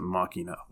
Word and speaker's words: Makima: Makima is how it's written, but I swear they pronounce it Makina, which is easier for me to say.